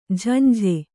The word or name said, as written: ಝಂಝೆ